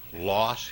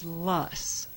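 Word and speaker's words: Loess: Is it Less Hills, Loss Hills, Less Hills? – Well, it's Less, and it rhymes with fuss. And it stems from a German word, and so we've heard it pronounced many different ways.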